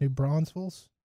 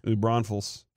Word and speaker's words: New Braunfels: That's New Braunfels. – How did you say that? – New Braunfels. – Okay. – New Braunfels.